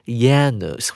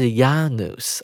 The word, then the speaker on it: Janus: 其实呢，这整个一月份 is named after the Roman god Janus。哦，这是英文的念法了，Janus。其实在当年很可能是念成 Janus 或者是 Janus 啊，是当时的可能跟拉丁文有关的一个发音。